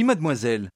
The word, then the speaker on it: mademoiselle: Oui, mademoiselle.